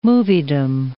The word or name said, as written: moviedom